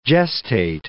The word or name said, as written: gestate